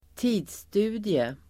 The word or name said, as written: tidsstudie